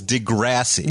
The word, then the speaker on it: Degrassi: I think it's Degrassi. – Degrassi. – Like grass.